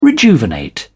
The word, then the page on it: rejuvenate: British English